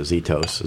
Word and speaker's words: Zythos: We're thinking about doing your late it looks like Zythos, so we pronounce it Zitos.